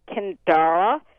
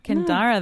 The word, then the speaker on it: Cyndara: It's pronounced Kindara, spelled C-Y-N-D-A-R-A. And her middle name, Louise, was a name that was used on both sides of the family. And it's a nice blend. – Ah, Kindara, that's pretty.